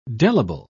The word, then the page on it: delible: British English